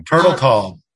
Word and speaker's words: Turtletaub: Turtletaub.